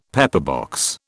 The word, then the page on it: pepperbox: British English